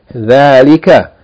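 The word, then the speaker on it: Dhalika (ذلك): Note, it says dhalika is pronounced as though there's an alif written after the letter tha, thal. Tha, fatha, alif. There's no alif written, but you have to pronounce it as though there's an alif written for elongation. Dhalika.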